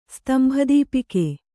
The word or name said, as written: ಸ್ತಂಭದೀಪಿಕೆ